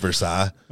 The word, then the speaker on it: Versailles: It's spelled Versailles, but we pronounce it Versailles.